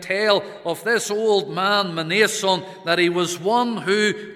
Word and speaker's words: Mnason: But the way to perhaps help with pronunciation is to insert a vowel there between the M and the N and to call him Mnason.